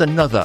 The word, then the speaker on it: Anotr: It's pronounced Another, apparently. Spelt A-N-O-T-R.